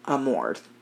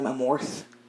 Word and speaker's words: Amorth: And I was pronouncing his last name Amorth, because that's how it's spelled. – Oh. – Naturally, I was fucking wrong. – Of course. – Yeah. It's actually pronounced Amort. – Oh, okay. – That H is silent.